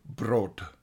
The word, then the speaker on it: brood: I'd like to thank Jack for getting in touch and correcting our pronunciation of the Dutch word for bread is in fact pronounced brood.